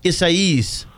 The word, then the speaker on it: Isaias: I never pronounced this right. Is it Isaias? – Isaias.